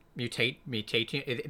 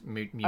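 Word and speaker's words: Mutationem: So on this list, otherwise we've got Dead Cells, Into the Breach, Anno Mutantium. – It's a really hard to say name. Mutatium. That is spelled correctly. It's Mutatium. – I put Dark Cloud 2 on this list, and H-A-A-K.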